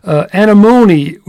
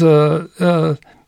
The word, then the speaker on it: Anemone: Anemone would be the proper way to say the botanical name, Anemone. But for us plebeians, Anemone. Three species of anemone, anemone found in Arizona, but tuberosa is the real borderland species.